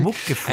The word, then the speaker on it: Muckefuck: Sollte man auch nicht Englisch aussprechen. – Muckefuck.